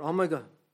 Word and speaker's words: Omega: Revelation 22 and the verse 13 says this, I am Alpha and Omega, or Omega, as some people pronounce it, the beginning and the end, the first and the last.